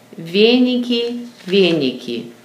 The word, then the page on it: Véñiki: Ve´n~iki